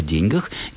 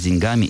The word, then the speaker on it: деньги: Но язык развивается, как любой живой организм, и с 20 века ударение слова «деньги» переместилось в другую, скажем так, парадигму акцентологическую, и ударным становится окончание в «деньгах», с «деньгами» и так далее.